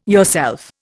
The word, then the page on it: yourself: Indian